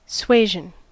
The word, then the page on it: suasion: American English